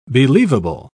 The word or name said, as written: believable